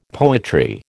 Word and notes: poetry: American English

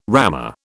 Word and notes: rammer: British English

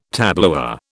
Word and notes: tableware: British English

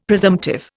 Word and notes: presumptive: Indian

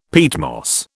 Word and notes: peatmoss: British English

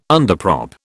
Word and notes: underprop: British English

